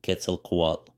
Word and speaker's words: Quetzalcoatl: Quetzalcoatl, pronounced Quetzalcoatl, or the wind god, decided to wander the plain and explore his creation.